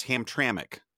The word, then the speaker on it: Hamtramck: Also, thanks to all the Michigan people who wrote in, we were confused by Hamtramck, and obviously it's Hamtramck.